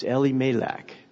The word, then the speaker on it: Elimelech: But as I've been studying and trying to understand this from the Jewish perspective, I found that the way they pronounce his name is Elimelech, with the emphasis on the L, which stands for God, because his name means God is my king.